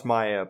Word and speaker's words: Maja: Is it Maya or M-A-J-A? – M-A-J-A. So Maya, yeah. It's pronounced Maya, but yes.